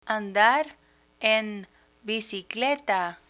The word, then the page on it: andar en bicicleta: Spanish